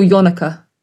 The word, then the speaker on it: Yonaka: So he goes, is it New York like yo? I'm like, yes, I believe it's yo. – A lot of people say it like that. – Yeah, some people go Yonaka. – Yonaka. – Yon. – Well, I can say Yonaka because it is a Japanese term. – It is. – So, but there we go. – They say we got taught how to say it, didn't we? It was like very like on one. It's like, but it's still Yonaka.